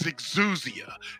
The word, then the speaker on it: exousia: The Greek word for power is exousia.